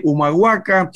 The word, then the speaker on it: Humahuaca: allí en la calle Humahuaca 3759.